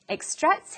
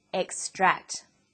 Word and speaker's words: extract: Where is the emphasis or stress in this word? It's on the second syllable, extract. But extract is also a noun. When it's a noun, it's pronounced extract. The emphasis is now on the first syllable.